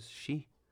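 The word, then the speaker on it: shi (四): El número 4 es shi y la muerte también se pronuncia shi.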